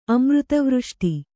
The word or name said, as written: ಅಮೃತವೃಷ್ಟಿ